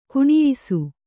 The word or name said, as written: ಗುಣಿಯಿಸು